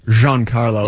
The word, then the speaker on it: Giancarlo: Giancarlo. Let's Giancarlo Stanton? Yeah. – It's not Gene. You call him Gene. It's not Sister Gene. – Giancarlo.